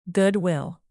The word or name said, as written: goodwill